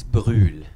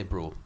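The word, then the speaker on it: Brühl: It's Bruhl, actually. Bruhl. But you can say Bruhl.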